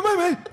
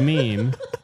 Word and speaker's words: meme: You got cut on a Maymay. – Yep. And now I'm catching an assault and battery charge because it's pronounced meme.